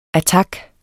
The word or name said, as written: attak